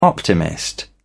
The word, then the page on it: optimist: British English